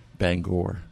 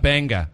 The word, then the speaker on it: Bangor: Now, what's the proper Maine pronunciation of Bangor? – Bangor. – Bangor. Okay, not Banga or no.